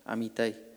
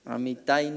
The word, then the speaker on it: Amitai: ¿Tiene el acento en la I? Entonces no me hagas quedar mal. Amitai. Amitai, no hay un acento ahí.